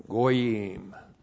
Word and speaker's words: goyim: G long O Y I M, goyim.